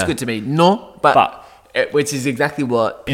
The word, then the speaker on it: Nantes: I don't know how to pronounce that. It's written as Nantes. – Yeah, I was going to say Nantes. – I think it's just non.